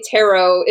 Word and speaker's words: tarot: It's almost like I say tarot. It doesn't rhyme with carrot. People say carrot.